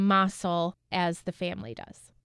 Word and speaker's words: Mossell: And I think our two guests, Heidi and Melissa, have told us that in Lockport, it's actually pronounced as Mossell, while the family pronounces it Mossell. So we've chosen to go by the family and call the family the Mossell family.